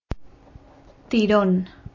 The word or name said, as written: tyronne